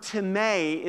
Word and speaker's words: timē: The word timē is actually how it is pronounced.